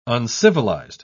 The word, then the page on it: uncivilised: British English